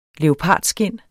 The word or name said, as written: leopardskind